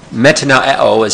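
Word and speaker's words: Metanoeo (μετανοέω): Metanoeo is how you say that. It's kind of hard to say.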